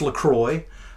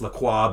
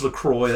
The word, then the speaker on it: LaCroix: But it is pronounced LaCroix. I know there are some Francophiles who want to freak out about that and say it's LaCroix, but it is LaCroix.